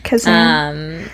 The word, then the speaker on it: Kazan: Kazan.